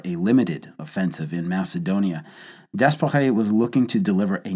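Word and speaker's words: d'Espèrey: Guillaumat was transferred back home in June of 1918, and French General Louis Franchet Desperet took over. Desperet was nicknamed Desperate Frankie by his British subordinates. But this illustrated more the inability of the Brits to properly pronounce his name than it did his personality.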